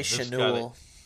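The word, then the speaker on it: Schanuel: It's S-C-H-A-N-U-E-L. Chanel. That one I don't know. – I'd say Chanel. – Chanel.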